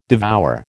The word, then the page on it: devour: American English